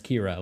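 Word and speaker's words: Qi'ra: I saw somebody whose name was QI-RA. I was like, who was this character? I don't remember that. And then I was like, oh, that's Kira.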